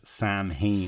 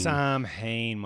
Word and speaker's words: Samhain: Not pronounced Samhain. – Samhain, my goodness.